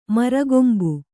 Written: ಮುರಿಕೊಂಬು

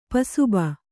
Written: ಪಸುಬೆ

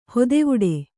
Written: ಹತ್ತೆವಿಡಿ

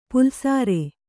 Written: ಪಲ್ಲಿಸರ

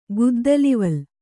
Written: ಗುದ್ದಲಿವಲ್

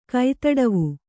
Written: ಕೈತಡವು

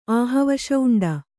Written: ಆಹವಶೌಂಡ

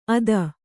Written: ಅದ

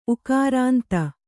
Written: ಊಕಾರಾಂತ

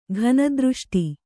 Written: ಘನದೃಷ್ಟಿ

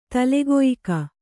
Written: ತಲೆಗೊಯಿಕ

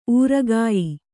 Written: ಊರುಗಾಯಿ

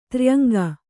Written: ತ್ರ್ಯಂಗ

